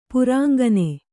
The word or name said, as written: ಪುರಾಂಗನೆ